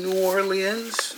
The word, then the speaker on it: New Orleans: Well, I arrived here in New Orleans. Did I say it right? You know, I came here. – Sure. – And you might as well spell it L-E-E-N-Z, from what I understood.